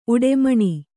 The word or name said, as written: ಉಡೆಮಣಿ